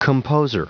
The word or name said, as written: composer